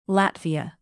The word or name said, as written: latvia